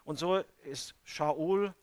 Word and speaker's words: Shaul: Ich habe mal gelesen, die Griechen konnten kein Sch aussprechen. Da haben die aus allem Sch und S gemacht. Es heißt Shabbat und sie sagten Sabbat. Es heißt Shaul und sie sagten Saul.